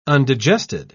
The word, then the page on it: undigested: British English